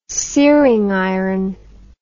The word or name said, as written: searing iron